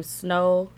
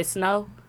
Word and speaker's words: Snoh: I think she pronounced her name Snow. – Fall. – Allegra. – Out. – Allegra. – S-N-O-H.